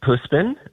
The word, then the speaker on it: Puspin: Puspin, how do you say that? – It's a sweet Puspin.